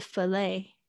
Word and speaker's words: fillet: OK，这边注意一下，fish fillet，呃，fillet 这个这个有两个发音，有一些人会念 fillet，啊，这个呢，呃，它的 t 也是没有发音的，啊，fillet，啊，就是指这个排状，OK，所以呢，那个菲力牛排呢，就是 fillet，就是这个字来的。